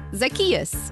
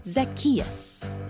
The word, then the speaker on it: Zacchaeus: Uh, like this Zacchaeus. Zachachusa. No. Zazacchino. – What? No, it's like Zach. Just say it after me. Zacchaeus.